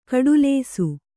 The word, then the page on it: ಕಡುಲೇಸು: kaḍulēsu